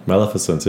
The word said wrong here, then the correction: Maleficent: We're not saying it right. Maleficent.